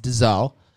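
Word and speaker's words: dzoe (ζωή): The Greek word for life is the word zoe, and it's pronounced dzoe.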